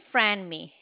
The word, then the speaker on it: Franmi: My name is Franmi. It's spelled F as in Frank, R-A-N-M-I. – Okay. Franmi, you were very helpful.